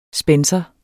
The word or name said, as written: spencer